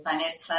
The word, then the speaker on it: Vanezza: Y en España yo aprendí a pronunciar mi nombre, es Vanessa, de verdad, así suena.